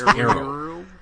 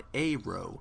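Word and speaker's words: Aaero: is Arrow.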